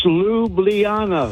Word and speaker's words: Ljubljana: I pronounced it Ljubljana. – Is that not right, Steve? – It's not right, but your call answer told me that you... It's Ljubljana. – Ljubljana. – So you left out a syllable.